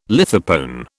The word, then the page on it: lithopone: British English